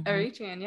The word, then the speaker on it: Eritrean: Eritrean.